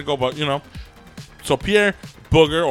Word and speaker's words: Bouguer: Earlier, I was looking up the pronunciation of Pierre's last name. One of them was Booger, and I was gonna go with Booger. – Pierre Booger.